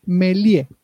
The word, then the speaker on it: Méliès: Acá te dicen, se pronuncia Méliès. – Méliès, ahí está, gracias, porque no me salía la pronunciación.